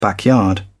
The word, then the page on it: backyard: British English